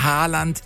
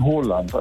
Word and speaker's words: Haaland: Erling Haaland ist falsch, ne? – Ja, das ist absolut falsch. Er wird ausgesprochen Haaland, also mit O, ja.